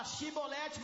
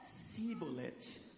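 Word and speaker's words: Shibboleth: Aí eles tinham que falar shibolete, mas eles falavam sibolete. Parece que tinha uma diferença na língua, não é? E eu vou explicar. Não conseguiam pronunciar.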